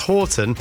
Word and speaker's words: Houghton: Brackets the dad, pronounced Horton,